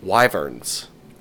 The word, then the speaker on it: wyverns: And that is, they're technically wyverns.